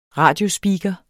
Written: radiospeaker

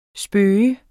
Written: spøge

